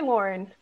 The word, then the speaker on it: Loren: It's actually Loren, but it's confusing because I was named after Sophia Loren. Oh. So I guess either one can go, you know? – Because I'm like, Loren de la Cruz.